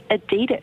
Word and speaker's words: Adidas: and apparently they're pronounced Adidas.